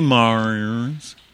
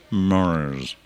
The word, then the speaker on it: Mraz: But as for the Mraz, say after me, Mraz. Mraz. Say it again. Mraz. Sounds like a...